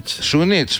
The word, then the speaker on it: Σούνιτς: Σούνιτς, καλά το πάω. – Σούνιτς, μάλιστα, ναι. Χωρίς γάμα. – Χωρίς γάμα.